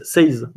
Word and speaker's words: Seize the Day: Je pense que ça se dit Seize. – Ah ouais, peut-être. – Seize. Non, pas la taille, oui, pardon. C'est pas Seize. C'est Seize. – C'est pas plutôt, Super Seize Me.